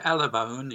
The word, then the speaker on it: Alabone: Yeah, ALABONE, yes.